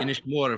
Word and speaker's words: Inishmore: Inishmore, of course, is not one of the good Aran Islands, because like my friends from Inisir say, well, it rhymes with whore.